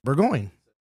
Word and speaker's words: Burgoyne: Burgoyne. – Burgoyne? – Yes, but I just kind of do I rolled my R's this time.